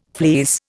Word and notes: fleece: Indian

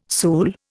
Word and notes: sool: Indian

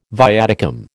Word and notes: viaticum: American English

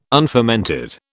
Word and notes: unfermented: British English

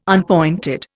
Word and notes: unpointed: Indian

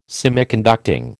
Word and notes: Semiconducting: American English

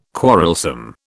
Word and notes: quarrelsome: British English

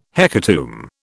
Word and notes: hecatomb: British English

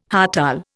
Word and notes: hartal: Indian

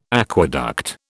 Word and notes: aqueduct: British English

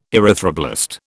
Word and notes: erythroblast: British English

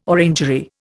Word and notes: orangery: Indian

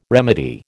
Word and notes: remedy: American English

